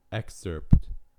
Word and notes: excerpt: American English

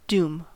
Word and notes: doom: American English

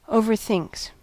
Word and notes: overthinks: American English

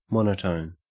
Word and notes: monotone: Australian English

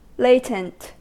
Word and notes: latent: American English

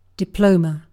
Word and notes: diploma: British English

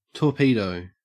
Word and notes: torpedo: Australian English

